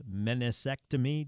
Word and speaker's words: meniscectomy: We were talking about Dyson Daniels, whom the Pelicans decided, I guess he decided with the Pelicans to have the meniscectomy. Doc, am I saying that right? – That's right. That's exactly how you say it, which is trimming out some of the meniscus.